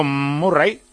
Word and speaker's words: Murray: El punto definitivo, que era el 3-1, lo consiguió Murray, que es como se pronuncia.